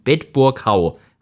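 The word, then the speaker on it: Bedburg-Hau: Und vom Heisterfelshof in Bedburghau. Habe ich das richtig gesagt? – Ja, genau.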